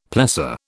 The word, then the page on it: plessor: British English